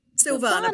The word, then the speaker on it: Silvana: Let me make sure I say it right. Silvana or Silvana? – Silvana, but it's all right.